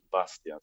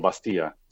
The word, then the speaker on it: Bastia: Però el més popular de tot és el Bàstia, sí? Mm-hmm. O el Bastia, sí. Mm-hmm. Hi ha moltes pronunciacions, si és Bastia, Bastià o Bàstia, però sí, crec que és el més popular entre el moviment nacionalista.